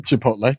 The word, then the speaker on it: Chipotle: You can support your local go-tos or choose from your favorite national restaurants like Chipotle. Is that how you say it? – Chipotle.